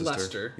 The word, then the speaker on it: Leicester: Well, it's pronounced Leicester. – Yeah, Leicester. – Unless you're from Leicester, then you pronounce it Leicester.